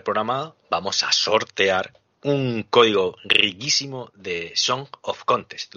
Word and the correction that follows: Song of Conquest: ¿Lo he dicho bien, Alberto? – Song of Conquest, perfectamente.